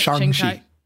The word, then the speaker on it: Shang-Chi: The Academy Award nominations were announced, and the four films released by Marvel Studios in 2021, only Shang-Chi, am I saying that right? – Shang-Chi. – What? – Shang-Chi. – Shang-Chi. Shang-Chi. Ooh, God, I was so wrong. Say it again.